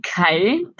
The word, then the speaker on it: CHI: Die Kai heißt ausgesprochen Computer Human Interaction, spricht sich einfach schöner nacheinander als HCI. ähm, deshalb heißt sie wohl Kai.